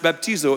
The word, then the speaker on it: baptizo: The word baptized in the original language is pronounced baptizo.